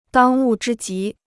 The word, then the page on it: 当务之急: dāng wù zhī jí